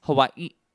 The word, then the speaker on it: Hawaii: Hawaii. It's Hawaii. That's how you're supposed to pronounce it.